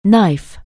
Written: knife

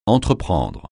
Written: entreprendre

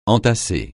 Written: entasser

